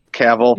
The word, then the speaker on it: Cavill: I was reading some things about the show where Henry Cavill, is that how you say his name? I don't know. Cavill. – Yeah.